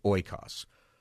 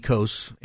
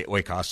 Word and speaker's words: oikos: You might pronounce it in modern Greek. It would be ekos, and it's the root word for ecosphere or economy. Academically, you typically say oikos, so that's what I'm going to say.